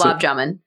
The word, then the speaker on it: gulab jamun: Gulab jamun.